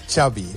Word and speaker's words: Xavi: Xavi. – Okay. Yeah, Xavi like chocolate.